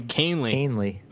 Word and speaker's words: Canely: Canely. – Canely, okay. – Canely, like Matt Cain and Cliff Lee.